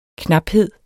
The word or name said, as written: knaphed